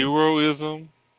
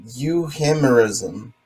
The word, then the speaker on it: euhemerism: Euroism? – No. It's euhemerism. That's got to be the way to pronounce it.